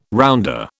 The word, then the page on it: rounder: British English